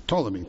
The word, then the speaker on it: Ptolemy: Judea and its capital, Jerusalem, fell under the control of Ptolemy, which had never been spelled with a P, but Ptolemy, P-T-O-L-E-M-Y, Ptolemy the First, who ruled the region from Alexandria, which was the center of Egyptian power.